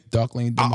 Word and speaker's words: Dark Lane Demos: How do you say that shit? – Dark Lane Demos. – Say it again. – Dark Lane Demos.